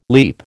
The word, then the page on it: leap: American English